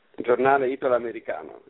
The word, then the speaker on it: Giornale Italo-Americano: Giornale Italo-Americano, yes.